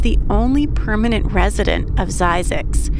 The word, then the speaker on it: Zzyzx: And now we're going to head northeast from the San Fernando Valley into the desert to the town of Zizix. That's spelled Z-Z-Y-Z-X.